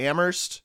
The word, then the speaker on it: Amherst: Just like the folks in Western Massachusetts don't want to hear any H when you talk about Amherst.